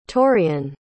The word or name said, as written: taurean